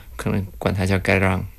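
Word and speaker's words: Cái Răng: 它越南语发音叫 Gai Rang，这个其实应该是一个当地话，就是高棉语的一个翻译。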